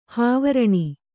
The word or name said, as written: ಹಾವರಣ